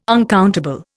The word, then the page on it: uncountable: Indian